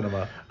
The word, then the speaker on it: Sinema: It, and Kyrsten Sinema, I don't know precisely how to pronounce her name, Sinema, yeah, Sinema.